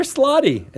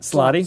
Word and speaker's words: slaadi: They're slotty, is what I found out. That's how you pronounce them. Mm-hmm. When how? – You knew that. Slotty. – Slotty. – Yeah. – Oh, that's what? – Plural. – S-L-A-A-D-I. Slotty.